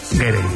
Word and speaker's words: Gere: Estaba pensando yo que si te apellidas Ger, bueno, ¿cómo se dice Ger o Gerde? – Gere. Me ha hecho gracia porque has dicho Richard Gere y luego el hijo era Gere.